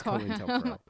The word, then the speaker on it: COINTELPRO: You know, that is when COINTELPRO was something. – Oh, is that how you say it? – Yeah, it's COINTELPRO.